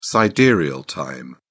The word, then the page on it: sidereal time: British English